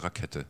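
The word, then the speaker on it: Rackete: Eigentlich Rakete oder Rakete? – Rakete, Aussprachedatenbank der ARD.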